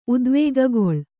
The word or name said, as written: ಉದ್ವೇಗಗೊಳ್